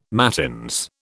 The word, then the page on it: mattins: British English